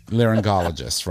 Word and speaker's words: laryngologist: laryngologist right right